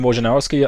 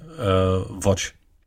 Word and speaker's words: Wojnarowski: Und deswegen hat er Adrian Wojnarowski, also das, habe ich den Namen richtig ausgesprochen gerade? Wojnarowski? – Ich habe schon sehr viele verschiedene Aussprachen dieses Namens gehört. Deswegen Watch. – Watch. – Ja. Woi. Das ist das, da bist du am sichersten, ja, glaube ich.